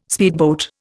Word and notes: speedboat: Indian

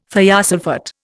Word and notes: thiosulphate: Indian

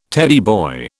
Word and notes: Teddy boy: British English

